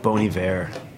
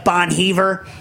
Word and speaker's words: Bon Iver: Like you pronounce it like it's French, not like Bon Heaver.